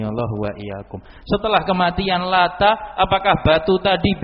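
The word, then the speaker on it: lata (اللات): Nah, batu ini bacanya lata. Kalau ditasydid nama orang, kalau takhfif tidak tasydid itu nama batu tempat buat roti disembah.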